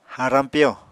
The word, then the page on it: harañ piyo: apatani